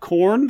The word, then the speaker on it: Khorne: Nope, it's spelled K-H-O-R-N-E. – Oh, but it still sounds like corn, like maize. – I did not mispronounce it. I pronounced it 100% correctly. It sounds exactly like the food and or band.